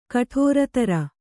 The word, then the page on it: ಕುಠಾರಧರ: kuṭhāradhara